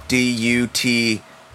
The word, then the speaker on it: Duterte: How do you say that name? Duterte.